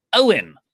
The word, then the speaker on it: Eoin: Owen Elaine. He's written how to say his name because it's spelled Irishly. E-O-I-N.